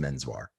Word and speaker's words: Menswar: So he changed it to what it actually sounded like, or how people attempted to pronounce it, which was just Menswar.